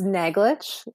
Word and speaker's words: Naglich: It's Naglich. It actually is very similar to how it's spelled. It's so funny how people, that CH at the end really appears as a challenge often.